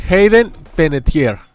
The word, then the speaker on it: Hayden Panettiere: Hayden Panettiere.